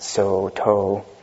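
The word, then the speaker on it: Sōtō: So the Sao Dong lineage, which in Japanese is pronounced So To, some say come from the names of these two ancestors.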